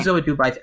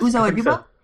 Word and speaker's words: Uzo Aduba: Uzo Aduba? How do you pronounce her name? – Uzo Aduba. I actually think that's right. – Nice. Yes, Uzo Aduba.